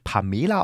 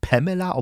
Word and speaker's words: Pamela: Und ja, sie wird tatsächlich Pamela ausgesprochen und nicht Pamela, obwohl sie nach einer Figur aus Dallas, einer Pamela Blablabla bla bla benannt wurde.